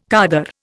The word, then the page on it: khaddar: Indian